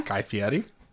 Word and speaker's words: Guy Fieri: Guy Fieri.